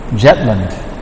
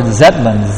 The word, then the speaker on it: Zetland: He said Lord Jetland. Apparently the Bengalis cannot pronounce the Z. So he said Lord Jetland. It's actually Lord Zetland. Z-E or Z they also say. Z-E. Lord Zetland is spelled Zetland.